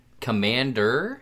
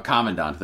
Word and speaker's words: commandant: Commander? – Commandant. – Commandant. – Commandant. – Like the Ten Commandments? – Commandant? – Oh, Commandant. There you go.